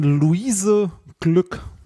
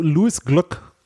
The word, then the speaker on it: Glück: Der äh, Nobelpreis für Literatur geht in diesem Jahr oder ging in diesem Jahr an Luise Glück. Okay. Oder Luis, nee, Lu, Luis Glück. Glück. Also sie schreibt sich Glück, wird aber anders ausgesprochen, äh, weil sie in den USA lebt. Ähm, der Nobelpreis für Literatur, muss man ja äh, sagen, ist ein bisschen der Skandalpreis unter den Nobelpreisen.